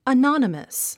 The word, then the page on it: anonymous: ənɑ’nəməs; アノォノマス